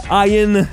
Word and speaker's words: Ian: Ian, c'est comme ça qu'on le prononce, sachez-le, Ian Ziering et Tara Reid seront de retour dans ce volet programmé en juillet prochain aux États-Unis.